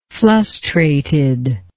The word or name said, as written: flustrated